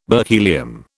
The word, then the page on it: berkelium: British English